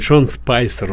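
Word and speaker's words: Sean Spicer: Шон Спайсер, уже если по-русски его произносить.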